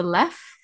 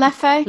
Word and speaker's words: Leffe: All they had was the Leff. That was the blonde. Leffe. Is that how you say it? Leffe? – Leffe. – Leffe. Yeah. I just assumed the second he was silent. Not apparently. So yeah, they had that blonde and I was like, oh, is this what I needed or was it definitely Hoegaarden?